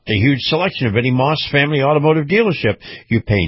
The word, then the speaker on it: Maus: Now, Moss Family Automotive is spelled M-A-U-S, Moss Family Automotive Group.